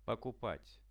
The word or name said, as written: pokupat